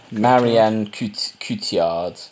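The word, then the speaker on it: Marion Cotillard: Marilyn Cotillard? – Marianne Cotillard, yeah.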